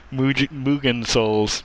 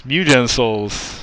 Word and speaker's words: Mugen Souls: The worst RPG named Mugen Souls. Mugen Souls. – I was going to say, you pronounced it correctly. – You pronounced it correctly and then corrected yourself to something that is incorrect.